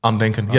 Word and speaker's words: Andenken: How do you pronounce it? – Andenken, yeah, German Andenken, yeah.